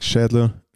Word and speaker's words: Schädler: I mean, it's fine. It's like Schädler. It's like just any kind of a butcher name.